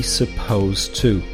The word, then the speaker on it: supposed to: The pronunciation is with an S sound, supposed to, and the to kind of blends with the final T sound of supposed, with spelt with an ED, of course, but then the last sound of supposed is a T that kind of blends with the T coming after it, and then, of course, after supposed to, you can blend the two T's, and then you will have an infinitive right away.